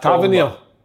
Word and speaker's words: Tavernier: Tavernier, which is, how do you say it? – Tavernier. – Aye.